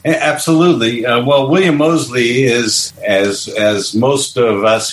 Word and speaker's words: Mosley: Your lead characters, William, is it Mosley? Is that how you say it? – Correct.